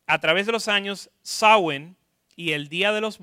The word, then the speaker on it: Samhain: A través de los años, y estamos hablando de esto, a través de los años hay una fiesta que está escrito Sam Ain o Sam Ain, pero de hecho se traduce Samhain. No sé por qué, pero así se traduce. Eh, se traduce, no se pronuncia.